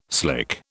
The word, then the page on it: slick: British English